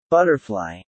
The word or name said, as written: butterfly